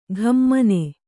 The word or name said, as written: ಘಮ್ಮನೆ